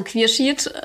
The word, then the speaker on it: Quierschied: Also Quierschied ähm, spricht man das mhm. aus.